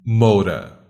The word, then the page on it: moura: /ˈmow.ɾɐ/; /ˈmow.ɾa/